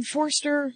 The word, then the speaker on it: Forster: Forster.